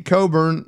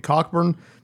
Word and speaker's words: Cockburn: Kofi Coburn, which, by the way, is, I said this last week, it's spelled Cockburn. And just out of principle, I'm going to continue to call him Kofi Cockburn because I don't know how a C and K can be silent.